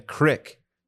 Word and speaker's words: creek: People say crick, but it's not like some. It's not like the normal pronunciation of it. It's actually the 17th century representation of creek.